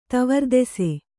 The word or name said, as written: ತವರ್ದೆಸೆ